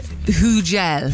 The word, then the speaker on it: Hugel: How do we say it? – Hugel. – Hugel. – Hugel. – H-U-G-E-L.